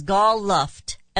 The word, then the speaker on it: Gal Luft: The whistleblower is Gal Luft, L-U-F-T. Gal, like gal, I think it's pronounced Gal, Luft.